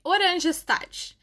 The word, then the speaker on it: Oranjestad: Hoje a gente vai falar sobre a cidade de Oranjestad. Mas como esse nome é muito difícil, eu vou recorrer aqui ao tradutor. A pronúncia correta é assim: Oranjestad.